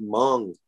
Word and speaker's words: Hmong: Where I'm at is a big Hmong, H-M-O-N-G, Hmong, you know, but it's spelled H-M-O-N-G.